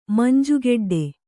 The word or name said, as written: ಮಂಜುಗೆಡ್ಡೆ